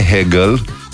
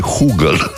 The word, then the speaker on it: Hugel: Nu e Hegel. Nu e din filozofie, e Hugel. – Da, cred că se pronunță Hugel. – Hugel. – Pentru că e francez. Oui.